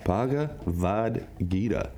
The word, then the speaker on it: Bhagavad Gita: And the Bhagavad Mother Flippin' Bhagita, I keep saying that wrong, Bhagavad Gita, says that's gets us into the highest state of spiritual union.